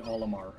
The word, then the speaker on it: Olimar: And the main character, his name is Olimar, right? – Yes. – Olimar. I don't know if I'm pronouncing that right, but yeah, it is indeed Olimar.